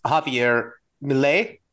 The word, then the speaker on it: Javier Milei: I don't want to mispronounce his name, but I believe it's Javier Milei.